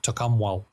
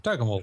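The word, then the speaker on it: Tocumwal: It's New South Wales, Victorian border area, and it looks like Tokumwal. But around here we pronounce it Tokemul.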